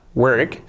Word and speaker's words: work: Fijaos la pronunciación, W-O-R-K. No sería wu, no, wu, work. Luego tenemos la R que casi se nos lleva la vocal anterior O, esta R fuerte y tan característica del inglés, y la K final, work, work, work.